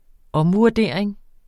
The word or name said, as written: omvurdering